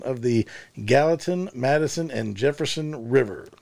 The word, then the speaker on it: Gallatin: Gallatin River.